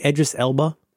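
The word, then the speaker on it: Idris Elba: Edris Elba? – Yes.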